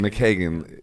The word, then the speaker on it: McKagan: Whereas now I'm becoming, I'm like actively working to, like when we were talking about Duff McKagan, how do you say McKagan. – His last name? McKagan, you know, how he became a business mind, you know, and how like that's real interesting.